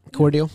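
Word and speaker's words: Cordele: Cordial? Yeah, okay. I've never even heard of that, and I don't know, this must be a Southern thing. You just know how to say Cordial.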